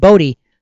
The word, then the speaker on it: Bodhi: He was Siddhartha, sat in the fig tree, and achieved Bodhi, so then he became Buddha. So that's why it's the silent H, BODHI.